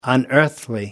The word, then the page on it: unearthly: American English